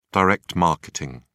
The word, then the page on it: direct marketing: British English